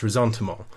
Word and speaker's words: ressentiment: And it's very much shaped, he argues, by grievance, victimhood, atrocity, this sense of injury, this sense of being persecuted, or what he calls resentment, or as it's properly pronounced, ressentiment.